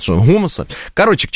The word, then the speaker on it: Homo sapiens: Тогда Homo sapiens, а не Homo sapiens или Homo sapiens. Homo. Какой Homo? Homo sapiens. Вот так вот надо правильно произносить. Как будто бы вы украинец.